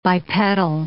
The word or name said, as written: bipedal